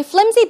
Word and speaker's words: flimsy: Okay, flimsy basically is an adjective, and as you see, the pronunciation is flimsy. Flimsy. It's not pronounced with an S sound, but it's closer to a Z sound.